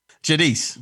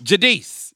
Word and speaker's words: Giudice: One of them, and we finally got his name right, Dominick Giudice. Giudice. Giudice. – Giudice.